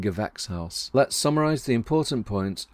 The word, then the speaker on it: Gewächshaus: And Gewächshaus is like vex.